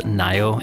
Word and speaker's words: NAO: That band N-A-O. – Nayo. – Not nay. – Not nair. No, I would say nair. – Now. – Well, I've actually looked it up and I know that it is Nayo. N-A-Y-O is how you would say it.